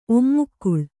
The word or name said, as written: ಓಮುಕಾಳು